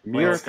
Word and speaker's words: Myrkur: How do you pronounce it? – It's M-Y-E. – Go ahead. – Mirker. Well, M-Y-R-K-U-R. – M-Y-R-K-U-R. K-U-R.